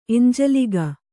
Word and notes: ಎಂಜಲಿಗ: Kannada

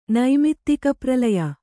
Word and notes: ನೈಮಿತ್ತಿಕಪ್ರಲಯ: Kannada

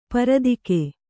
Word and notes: ಪರದಿಕೆ: paradike

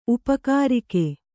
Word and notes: ಉಪಕಾರಿಕೆ: upakārike

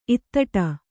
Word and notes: ಇತ್ತಟ್ಟು: ittaṭṭu

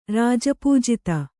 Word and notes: ರಾಜಪೂಜಿತೆ: Kannada

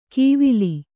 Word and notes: ಕೀವಿಲಿ: kannada

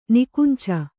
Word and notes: ನಿಕುಂಚ: Kannada

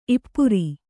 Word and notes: ಈಪರಿ: īpari

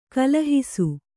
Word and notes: ಕಲಹಿಸು: kalahisu

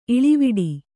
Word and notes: ಇಳಿವಿಡಿ: kannada

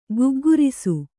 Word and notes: ಗುಗ್ಗುರಿಸು: guggurisu

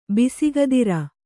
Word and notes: ಬಿಸುಗದಿರ: bisugadira

